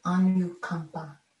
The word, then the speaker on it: anukampa: And what's cool is to remember that karuna, this meditative compassion, purifies compassionate action in the world, anukampa. That's spelled A-N, as in Nancy, U-K-A-M-P-A, anukampa. And in the suttas,